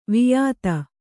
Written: ವಾಯಿದೆ

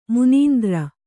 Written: ಮನಂದರ್

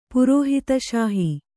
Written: ಪುರೋಹಿತಶಾಹಿ

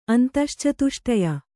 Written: ಅಂತಶ್ಚತುಷ್ಟಯ

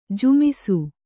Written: ಝೊಮ್ಮಿಸು

